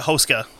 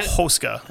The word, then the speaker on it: Hoska: Because apparently with Czech, with most words, you put the emphasis on the first syllable of the word. – I mean, it's so Hoska is how I've heard everybody else say it.